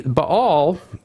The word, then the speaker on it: Baal: The word Baal, or in Semitic language, it's just B-L. And, you know, the A's are the dots and the tittle, so to speak. So it's Baal is the way you pronounce it.